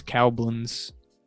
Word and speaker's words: Koblenz: Oh, it says I looked up pronunciation. It says Koblenz. Yeah, it says Cowblins.